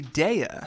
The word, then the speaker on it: Daya: Well, Joe, it's actually Daya.